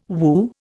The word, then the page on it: woo: Indian